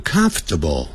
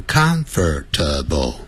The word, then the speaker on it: comfortable: 这个 comfortable 你念的时候不要念成 comfortable 不要念成四个音节念成三个音节 comfortable